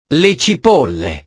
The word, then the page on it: le cipolle: italiano